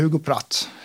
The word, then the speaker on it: Hugo Pratt: Han var döpt i Hugo, och, alltså, men Pratt är ju ett brittiskt efternamn, så att vi säger Hugo Pratt. – Ja, det är ju ett väldigt atypiskt italienskt namn, alltså för att italienska namn brukar ju sluta på en vokal. – Ja, Hugo Pratt.